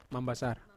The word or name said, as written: mambasar